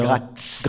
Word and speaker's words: Graz: Vous avez étudié au Conservatoire de Nice, puis au Conservatoire national supérieur de musique et de danse de Paris, puis en Autriche à Graz, si je prononce bien. – Gratz. – Graz, Graz.